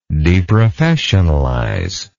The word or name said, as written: deprofessionalize